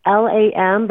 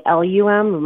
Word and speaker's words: LAM: It's LAM, but we kind of pronounce it more like LUM, LAM.